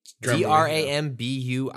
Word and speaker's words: Drambuie: Dram- Drambuie. – D R A M B U I E.